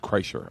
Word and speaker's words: Kreischer: Kreischer.